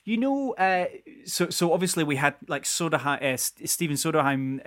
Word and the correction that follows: Sondheim: and is it Soderheim? I can't pronounce it. Is it Steven Soderheim? – Sondheim.